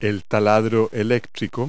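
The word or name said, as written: electric drill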